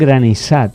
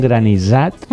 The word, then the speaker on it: granissat: això és un granissat, i no ho diríem bé perquè no s'escriu amb aquesta pronúncia sonora, sinó que és sorda, per tant va amb dues S, és granissat, doncs de cafè o de llimona.